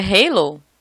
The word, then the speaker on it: Halo: Ao entrarmos em um assunto com o efeito ralo, tá certa a pronúncia? É ralo mesmo? – Você acha que é Halo? Não sei o que, que é esse efeito. em inglês? – Halo! Halo, é.